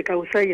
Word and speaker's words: Causae: El término es Summa Causae, porque el quichua es grave. Entonces, generalmente se dice Causae, es como el quicho.